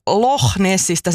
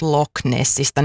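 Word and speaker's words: Loch Ness: Ja toisen puolen siksi, että puhuin Loch Nessistä sen sijaan, että olisin puhunut Loch Nessistä. Nyt korjaan tämän ääntämisvirheen ja pyydän anteeksi väärää ääntämystä.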